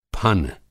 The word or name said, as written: फन